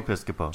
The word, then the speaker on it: Piscopo: Joe Piscopo.